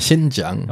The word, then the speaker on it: Xinjiang: Das ist die Hauptstadt der Provinz äh, Xiang, Xianyang. Ist das richtig ausgesprochen? – Xinjiang.